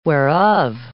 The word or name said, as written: whereof